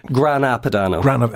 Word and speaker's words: Grana Padano: It's Grana Padano rather than... – Grana Padano. – Manuela, how's our pronunciation? Are we all right? – Yeah, you're doing good.